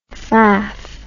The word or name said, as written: faff